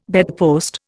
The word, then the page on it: bedpost: Indian